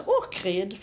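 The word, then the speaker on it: Ohrid: Och sen är det ju då Ockrid. Det uttalas Ockrid, fast det stavas med H.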